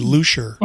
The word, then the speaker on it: Leuchter: So, or the way you pronounce it is really Luscher.